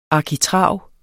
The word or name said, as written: arkitrav